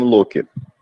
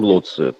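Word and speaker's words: loci: Он был именно вот глобальным гением Локи. Гением Лоцци. Тут может быть разница в произношении.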